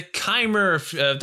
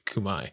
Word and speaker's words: Khmer: Next, we have the Kumai, the Khmer. That's how I used to pronounce it until I learned it's pronounced Kumai.